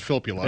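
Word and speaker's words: Filppula: Yeah, first off, it's pronounced Fipula.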